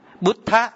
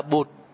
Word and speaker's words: Buddha: Buddha, chữ này đọc là Buddha, biết Phật, giác ngộ, dịch có nghĩa là biết. Giác, giác ngộ, Phật, Phật là phiên âm á. Cho nên ngài Nhật Hạnh đọc là Bụt, là do từ này ra.